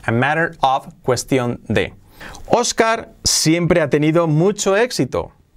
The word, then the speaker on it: Oscar: Oscar, fíjate cómo pronunciamos esta este nombre, Oscar es Oscar. La O casi como una A y la A casi una O porque se la come la R.